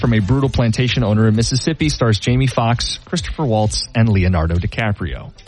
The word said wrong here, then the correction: Christoph Waltz: I believe it's Christoph Waltz. Christopher Waltz. – Oh, Christoph. – Christoph.